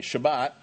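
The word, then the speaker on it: Shabbat (שבת): Even in Israel today, Shabbat, that's how you say it in Hebrew, you know, Shabbat is a very big deal.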